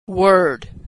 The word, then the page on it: word: American English